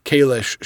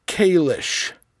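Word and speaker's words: Kahless: So, and by the way, all you Trekkies out there who think that I'm not saying it right, it's pronounced Kalish, not Kalis, Kalish.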